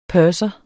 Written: purser

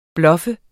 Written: bluff

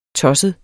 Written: tosset

